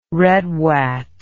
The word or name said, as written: redwat